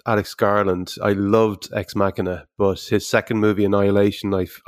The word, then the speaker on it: Machina: I'm not quite sure how you pronounce Machina. – That. Language isn't my strong suit. – But, it's Machina.